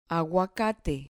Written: aguacate

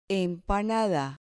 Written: empanada

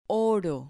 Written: oro

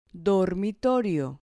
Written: dormitorio

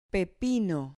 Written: pepino

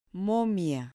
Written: momia

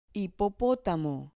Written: hipopotamo

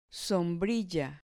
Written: sombrilla

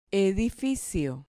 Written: edificio

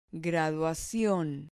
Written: graduacion

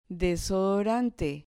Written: desodorante

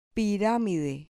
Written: piramide

